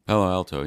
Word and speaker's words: Palo Alto: And Ken worked as a real estate consultant and Christine was a music teacher at the Palo Alto, am I saying that right? – Palo Alto, yeah.